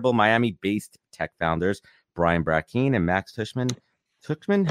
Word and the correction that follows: Tuchman: how does she pronounce her name? How does Max pronounce it? Tuckman, yes, for a panel discussion on all things Miami Tech.